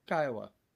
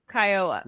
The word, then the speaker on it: Kiowa: Faster. Less syllable. Less syllably. Kiowa.